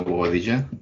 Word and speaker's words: Boadilla: And this is your co-host, Leo Boadilla. Still rhymes with Quesadilla.